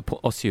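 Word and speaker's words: Osew: Do you think Paul Osue will... Osue is the right way to say that, isn't it? – Yeah.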